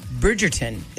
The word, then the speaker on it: Bridgerton: I got to tell you, I um, I have a lot of people buzzing about this Bridgerton. Is that how you say it? – Yes.